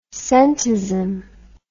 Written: scentism

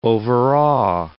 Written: overawe

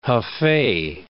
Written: Hofei

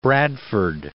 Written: Bradford

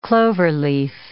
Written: cloverleaf

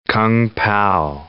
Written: kung pao

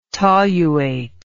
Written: toluate

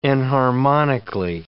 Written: enharmonically

